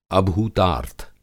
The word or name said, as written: अभूतार्थ